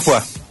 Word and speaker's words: foie: Silvana se suma para entradas para Illapu con criollitas, con paté, ¿cómo se dice? ¿De foie? – Sí, de paté de foie.